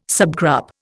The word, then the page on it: subgroup: Indian